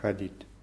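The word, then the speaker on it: chadit (ходить): Ja, chadit. – Ja, die Betonung ist auf i, chadit.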